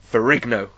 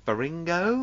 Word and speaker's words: Ferrigno: I got that right. – You got that right. – I didn't just butcher his name. Like, yeah, Matt will know the amount of times I try to say that name. Faringo.